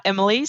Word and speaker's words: Emily: Well, you can find me on Twitter at Scicluna Emily. So my last name is spelled S-C-I-C-L-U-N-A and then E-M-I-L-Y.